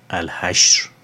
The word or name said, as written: Al-Hashr